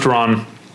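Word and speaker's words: Ran: But, okay, according to your Twitter pals, it's pronounced Ron.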